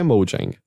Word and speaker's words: Mojang: Acho que é o que a gente fala Mojang. Tá bom. É como a própria Microsoft fala, eu acho que não é a pronúncia original correta, mas é Mojang.